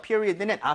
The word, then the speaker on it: period: period는 앞에 이에다 강세를 좀 많이 주시면 좋아요.